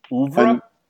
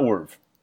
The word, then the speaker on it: oeuvre: Oeuvre? – Wait, okay. O-E-V... – Okay, I looked it up. It's O-E-U-V-R-E. – And it's pronounced oeuvre. – Oeuvre. Okay, so not URV.